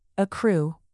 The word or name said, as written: accrue